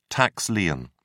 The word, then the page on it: tax lien: British English